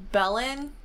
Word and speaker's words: Belen: And I think it's pronounced Belen, New Mexico.